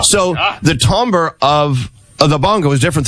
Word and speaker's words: timbre: It's timbre. – But it's spelled timbre.